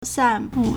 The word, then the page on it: 散布: sànbù